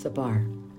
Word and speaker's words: Sefer: So just call this, some people say Sefer, but I found it's true pronunciation, ancient pronunciation is Sefer.